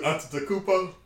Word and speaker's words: Antetokounmpo: You messed it up too, Antetokounmpo. – Yeah, that's how you say it. Antetokounmpo.